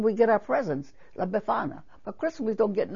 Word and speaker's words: Befana: Yeah, Befana, B-E-F-A-N-A, Befana.